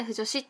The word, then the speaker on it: 腐女子: 女子っていうものに分類されるタイプの趣味でまあ腐女子っていう意味がわからない人もいると思うんですけど、まあ、草、まあ、ふ物が腐敗するのふに女子の女子って書いて腐女子って読むんですけど、